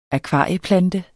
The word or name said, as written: akvarieplante